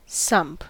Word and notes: sump: American English

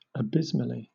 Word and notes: abysmally: British English

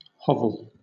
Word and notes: hovel: British English

